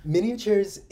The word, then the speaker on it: MNiatures: It's spelled MNIATURES, really highlighting the fact that this is a way that we're thinking about the Minnesota community, right?